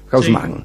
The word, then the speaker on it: Haussmann: En 1853, el día en que Haussmann prestó juramento, como digo, la pronunciación que se usa en París, Haussmann.